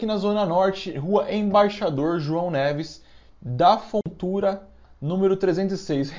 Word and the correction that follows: Fontoura: Repetindo que eu falei errado, de tão bosta que é esse bar. Rua Embaixador João Neves, da Fontoura, número 306.